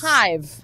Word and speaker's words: Haive: That's spelled H-A-I-V-E, right? – Yes. – Hive.